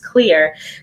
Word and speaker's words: Xlear: So Clear is a nasal, well, X-L-E-A-R is how they spell it, but it's pronounced Clear.